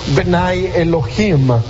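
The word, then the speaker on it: Bene Elohim: En el Antiguo Testamento, siempre que se menciona hijos de Dios, es esa palabrita que se pronuncia Benai Elohim. Benai Elohim, hijos de Dios.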